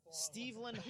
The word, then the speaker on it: Steveland: And I always pronounce it wrong, but it's Steveland Hardaway Junkins.